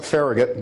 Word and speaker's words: Farragut: I know they pronounce it Farragut back in Washington, Farragut, it's a Catalan name.